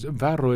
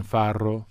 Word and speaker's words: farro: One is farro is an ancient relative of wheat. You see it in Italian cooking a lot. It is slightly ridiculous to say it correctly in Italian. Farro.